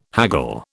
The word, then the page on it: haggle: British English